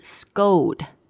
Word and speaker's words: scold: OK，它其实就是在 cold 前面加一个 s 啦，但是它的发音不是 scold，是 scold。 – Scold, that's right. So the C here is going to sound more like a G, G.